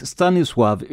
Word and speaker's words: Stanisław: Och Stanisław uttalas just så i det här fallet, eftersom l har ett snedstreck igenom sig och på polska då uttalas som engelskans W, w Stanisław hade en särdeles jobbig uppväxt i sitt hemland Polen och det blev inte direkt bättre när han kom till Sverige.